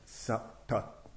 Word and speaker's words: Sattha: So the term Satta, S-A-T-T-H-A, with two little dots under both the T's, one dot under each T, so it's pronounced something like Satta. I can't do it properly, it's one of those palatals.